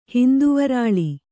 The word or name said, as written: ಹಿಂದುವರಾಳಿ